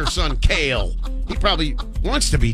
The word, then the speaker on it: Kale: K-A-L-E. K-A-L-E.